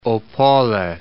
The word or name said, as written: Opole